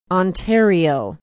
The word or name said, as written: ontario